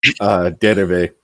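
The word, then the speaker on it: dérive: Pronounce it with a bad French accent the way I do. Derive.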